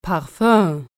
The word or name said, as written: Parfum